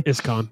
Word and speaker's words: ISKCON: ISKCON.